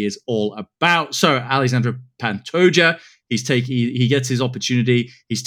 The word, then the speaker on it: Pantoja: I pronounce his name differently every time I say it. I heard John Anik say Pantoja recently, and if it's good enough for John Anik, it's certainly good enough for me.